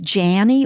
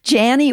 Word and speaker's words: Janie: My name is Janie Ortland. It's kind of a goofy name. It rhymes with Annie, Granny, Fanny. Janie Ortland, and I'm married to Ray Ortland Jr. We have four married children and 14 grandchildren, at least at latest count.